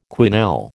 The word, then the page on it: quenelle: American English